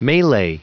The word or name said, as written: melee